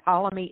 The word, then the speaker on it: Ptolemy: And Ptolemy is spelled P. T-O-L-E-M-Y. The P is silent, like with those ancient birds, you know.